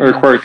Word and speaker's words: Urquhart: On salue au passage Corey Urquhart ou quelque chose, parce que c'était le gars, je pense, que le Canadien avait repêché. – Urquhart, oui. – Urquhart, exact. Merci pour la prononciation plus appropriée.